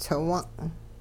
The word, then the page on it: tsiwung': hupa